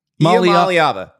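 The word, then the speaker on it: Iamaleava: If you're listening to this on Monday, you can go on The Athletic and read my story about Nico Iamalea. Nico himself told me how to pronounce Iamalava. – Iamalava. Iamalava. I've got it now.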